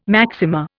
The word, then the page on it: maxima: Indian